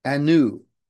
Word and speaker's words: anu: The Hebrew word for with is pronounced im, like E-E-M. And the Hebrew word for us is anu, A-N-U. Put them together and say them together. It's im anu, im anu. The Hebrew word for God is El, E-L. Im anu El.